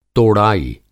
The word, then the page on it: तोड़ाई: Hindi